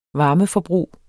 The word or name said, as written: varmeforbrug